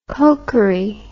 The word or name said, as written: cokery